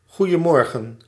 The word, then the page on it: goeiemorgen: /ˌɣujəˈmɔrɣə(n)/